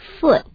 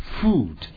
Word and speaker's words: foot: Speed, in, keep, owes, take, one, by, foot. – Foot, 可不要念成 food。如果念成 food 是 F-O-O-D, 食物，我们吃的东西。